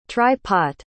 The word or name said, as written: try-pot